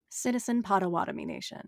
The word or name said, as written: Citizen Potawatomi